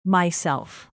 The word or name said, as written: myself